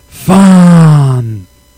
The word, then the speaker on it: Fandango: It is Fandango.